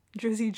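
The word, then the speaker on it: Drys: Oh, so it's not Dries, it's Driz? That's not good either. But it's spelled D-R-Y-S. I'm assuming they mean Drizzy Drake? I hate both options. A little different than the actor Idris, but still elegant sounding. It's definitely Driz.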